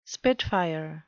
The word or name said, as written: spitfire